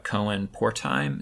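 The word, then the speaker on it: Cohen-Portheim: Cohen Portheim, am I pronouncing his name correctly? – You are.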